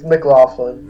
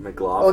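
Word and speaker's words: MacLachlan: I don't know how to say his name. – It's McLaughlin. – McLaughlin.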